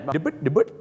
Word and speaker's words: debt: ฮะอย่างเช่นคำนี้ครับคำว่า receipt ที่แปลว่าใบเสร็จนะครับบ้านเราชอบออกเสียงว่า receipt นะฮะ receipt ไม่ใช่ receipt นะฮะก็จะออกเสียงว่า receipt นะครับผม receipt นั่นเองนะฮะเหมือนกับคำนี้ครับคำว่าหนี้สินนั่นเองนะฮะหนี้สินเนี่ยนะครับก็คือ debt นะครับไม่ได้ออกเสียงว่า debt แล้วก็ไม่ได้ออกเสียงว่า debt ด้วยนะฮะ debt นะฮะเขาออกเสียงว่า debt นั่นเองครับตัว B จะข้ามไปเลยนะครับก็